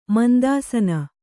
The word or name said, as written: ಮುಂತಾಸನ